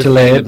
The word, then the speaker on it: Tlaib: We just voted into Congress a lady from it's Representative Rashida Tlaib, I think I'm pronouncing that right, Democrat from Detroit. – You have to kind of do that Tlaib back to your throat when you do it.